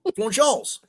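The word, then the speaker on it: Florence Y'alls: I could have watched this at the Florence Urals on a Friday night and been quite content. – Did you just call them the Florence Yorals? – Yalls. Florence Yalls.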